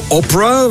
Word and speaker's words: Oprah: En iedereen zegt toch Oprah? Hoe komt dat nu? Wel, niemand kon het uitspreken vroeger, die Orpah. En dus begonnen ze gewoon Oprah te zeggen.